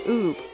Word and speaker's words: Ubbe: And while we know him as Ub today, that's U-B, he was originally born U-B-B-E, pronounced Oob, according to Jeff.